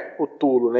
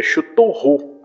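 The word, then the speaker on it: Cthulhu: que a pronúncia não é, não é cutulo, né, ele fala lá na hora pro, pro editor, é chuturru.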